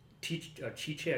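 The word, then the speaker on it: Tite: Chiche, I guess is how he pronounce it.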